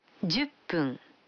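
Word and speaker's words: Дюппун (十分): Вы заметили, что когда фун добавляется к 10, дю, то произносится пун. – Послушайте и повторите каждую фразу. 5 минут. – Гофун. – 10 минут. – Дюппун.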